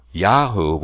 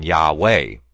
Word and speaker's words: Yahu: So Yahu would be a correct pronunciation rather than Yahweh.